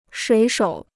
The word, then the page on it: 水手: shuǐ shǒu